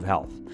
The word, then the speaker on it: HLTH: It's the largest and most important conference for health innovation. HLTH, pronounced health,